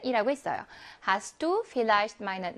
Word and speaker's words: Vielleicht: 자, 그러면 Vielleicht의 V는 F 발음을 냅니다.